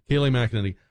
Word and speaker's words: Kayleigh McEnany: Is it Kayleigh McEnany? Am I saying that right, Don? – Kayleigh, yes. – Kayleigh McEnany.